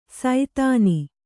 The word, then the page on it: ಸೈತಾನಿ: Kannada